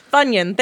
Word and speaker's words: Funyun: No, Funyun, thank you. That's how you pronounce it.